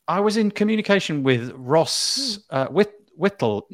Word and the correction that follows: Wintle: No, oh my goodness, I've um, Wintle. I've forgotten how to pronounce his surname. Hold on, let me just kind of find it quickly. Oh dear, Ross Wintle. – Yeah, has got an N.